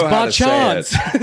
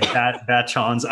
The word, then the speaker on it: Bachans: You said it correctly, by the way, in case anybody was wondering. – No, you didn't. It's Bachans. – Yeah, it's like Bachans.